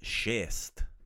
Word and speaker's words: šest: Number six is schist. – It kind of sounds like six, kind of.